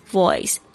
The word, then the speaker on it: voice: 比如说第一个是 your voice， your 就是你的， voice 就是声音 voice，大家特别注意一下这一个字的 V 的发音 V V V，我发现有很多同学都发错这个 V 的声音，你记得你的上排牙齿要有轻轻碰触到下排的嘴唇。还有这个 Oi 好像大家也发不好，因为中文并没有这个发音